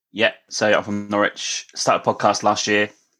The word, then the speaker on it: Norwich: Norwich.